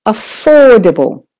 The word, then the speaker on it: affordable: So then you can hear how to stress the main stressed syllable, just make it louder and longer. Okay. Affordable. If you're doing the American, it's affordable. Affordable. So the or is still stressed.